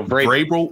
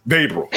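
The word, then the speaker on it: Vrabel: Thank you, Grable. I said it right that time? – No, Vavril. I usually don't do this. Whatever, Mike. Vavril.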